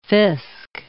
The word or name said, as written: fisc